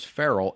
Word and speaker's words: Farrell: And my usual disclaimer, feral cat, I believe it's spelled F-E-R-E-L. My name is Farrell, F-A-R-R-E-L-L.